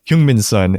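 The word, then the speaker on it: Heung-min Son: But he kind of brings out the most, and especially in this last game, Hyungmin Sun is, I learned that's how you pronounce it.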